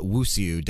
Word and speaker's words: Wuxia: but Wuxia, W U X I A. It borrows elements. – Wuxia. – Wuxia. Okay.